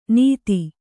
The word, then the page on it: ನಿಧಿ: Kannada